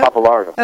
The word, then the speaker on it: Pappalardo: Pappalardo. – Pappalardo.